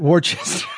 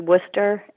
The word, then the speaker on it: Worcester: Well, you know, it's very weird, but it's Worcester. – See, I'm from Minnesota. We would definitely say that, Worcester. – You know, you're not the only one, so we've heard it a lot before. But it's pronounced Worcester, and Worcester.